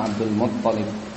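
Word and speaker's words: Abdul Muttalib (عبد المطلب): Nama Abdul Muttalib, toknya di tasdid, ya, Muttalib, ya. Kalau wazan mufa'ilun, ya, Muttalib. Fasara la yuzkar wa la yudha illa bihi.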